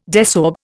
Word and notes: desorb: Indian

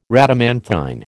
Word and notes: Rhadamanthine: American English

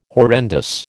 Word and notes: horrendous: American English

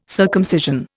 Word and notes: circumcision: Indian

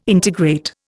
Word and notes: integrate: Indian

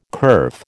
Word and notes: kerf: American English

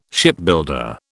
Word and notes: Shipbuilder: British English

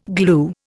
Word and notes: glue: Indian

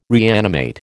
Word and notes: reanimate: American English

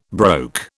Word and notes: broke: British English

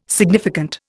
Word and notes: significant: Indian

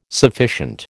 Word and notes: sufficient: American English

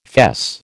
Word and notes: fess: American English

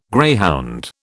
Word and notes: greyhound: British English

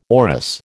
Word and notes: orris: American English

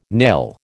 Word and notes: nail: American English

